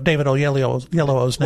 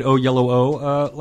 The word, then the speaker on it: Oyelowo: But nice pronunciation of David Oyelowo's name. Better than me. – I interviewed David Oyelowo last Thursday, and you can look for that interview also at We Are Movie Geeks next week.